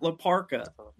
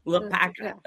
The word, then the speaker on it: La Parka: Say it right, not La Parka. La Parka.